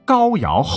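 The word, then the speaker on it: 皋陶: 陆安地区的建制其实啊和曹县一样历史悠久，在夏朝时属于高尧后裔封地。这个高尧的尧是个陶瓷那个陶哈，一定要读成尧。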